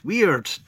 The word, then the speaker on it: Wyrd: It's Weird. W-Y-R-D. That's how it's spelled.